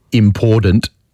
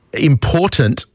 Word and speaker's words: important: important. It infuriates me. Important with a T doesn't seem that hard to say, and yet so many people get it wrong.